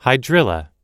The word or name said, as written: hydrilla